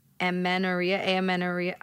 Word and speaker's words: amenorrhea: Someone wants to know what you have to say about amenorrhea. How do we pronounce this? – Yeah, that is correct. Amenorrhea.